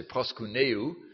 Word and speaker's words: proskuneo: I'll try to spell it for you if you want. P-R-O-S-K-U-N-E-O. Proskuneu. I think you would say. I would say proskuneu, pronouncing it more the German way.